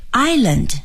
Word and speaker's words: island: 纽约市的一部分呢是在岛上，那么岛用英语说是 island, I-S-L-A-N-D, island. Island，请朋友们一定要注意啊，这个发音应该是 I，然后呢 land island。